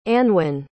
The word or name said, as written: anwen